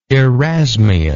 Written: erasmianism

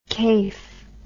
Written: kafe

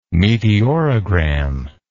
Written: meteorogram

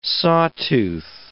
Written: sawtooth

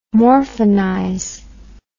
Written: morphinize

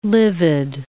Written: livid

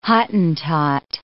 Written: Hottentot

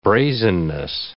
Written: brazenness

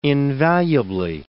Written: invaluably

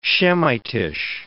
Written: Shemitish

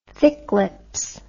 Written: thick lips

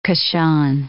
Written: Kashan

